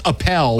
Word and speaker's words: Appel: It's Appel.